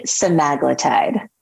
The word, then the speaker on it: semaglutide: And I do remember that clearly, and um, one of since then, obviously the the um, and by the way, do you pronounce it semiglutide or semaglutide or or something else? – I, I say semaglutide.